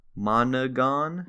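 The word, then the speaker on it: Monaghan: Monagon.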